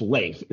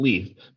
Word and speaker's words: Leif: Leif is the per- correct pronunciation. It's the name. It's Leif. It's not Leaf.